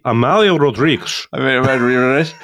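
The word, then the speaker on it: Amália Rodrigues: But Tom's pronunciation of Amelia Rodriguez. – No, that's Spanish again. It would be Amalia Rodriguez.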